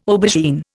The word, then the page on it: aubergine: Indian